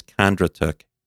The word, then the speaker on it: Kondratuk: So our last name is Kondratuk, K O N D R A T U K.